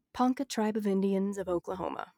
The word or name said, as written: Ponca